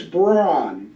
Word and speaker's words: Braun: Yeah, it's Braun. – Braun. – The German pronunciation.